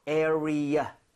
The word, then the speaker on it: area: 대신에 발음할 때 전에 말씀드렸죠. 자기도 모르게 area. 이렇게 해서 R 사운드 내는 경우 많다. Right. – 맞아요. – 그래서 area 하고 완전 끊어내세요. R 하지 마세요.